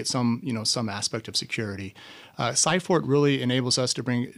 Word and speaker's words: Cyport: Well, when you talk about expanding, you recently purchased Cyport. Is that how you pronounce it? – Yes, Cyport.